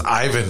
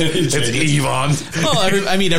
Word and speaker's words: Ivan: It was Ivan the whole time he was here in St. Louis. He goes out to Vegas. It's Ivan. – Everybody's pronounced. You ask John Hamm, even John Hamm pronounced it's Ivan Barbashev.